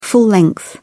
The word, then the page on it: full length: British English